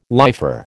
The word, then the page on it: lifer: American English